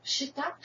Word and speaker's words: Peshitta: So probably the best way to pronounce it is Peshitta. Only that doesn't sound so good in English. But I say it's Peshitta.